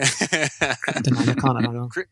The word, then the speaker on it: Cryptonomicon: Cryptonomicon. – Cryptonomicon. I knew there was an extra syllable in there. We'll say Cryptonomicon.